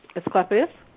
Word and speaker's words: Asclepius: Can you pronounce it? Asclepius? – Asclepius? – Yeah, what she said.